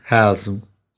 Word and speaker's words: Haslam: It's pronounced Haslam.